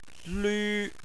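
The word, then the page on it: lv: tone 1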